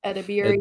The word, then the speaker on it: Edebiri: Ayo Edebiri. – Aduba, yeah. – Nope. – Oh, Ayo, isn't it the person from The Bear? – Yeah, but her last name's like Edebiri. Edebiri.